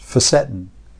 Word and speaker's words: fisetin: Well, I learned that it's pronounced facetin.